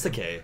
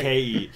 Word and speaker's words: Takei: No, it's Takei. He says it's okay to be Takei. – oh, okay. Like, as, as a right. joke, so. Right. Uh, come on, learn your Japanese pronunciation.